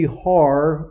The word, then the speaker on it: Har: That's the Hebrew word for mountain is you pronounce it Har with the H, but they don't, in Hebrew is R, but you pronounce it with a hard breathing.